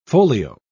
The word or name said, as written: folio